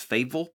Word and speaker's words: FABVL: It's spelled F-A-B-V-L. And when I heard him say his own name, it sounded like he pronounces it Fable.